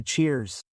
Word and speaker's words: cheers: Normally before having a drink with the group you're going to do a cheers. Okay, cheers. Z, you have to add the Z sound at the end.